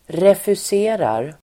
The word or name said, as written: refuserar